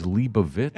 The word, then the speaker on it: Leibovitz: New correct pronunciation: Annie Leibovitz. – Oh no! – I'm like, you just fucked my charity, man. – He didn't do that. – He did. – The correct pronunciation is Leibovitz, and I didn't get the money for it.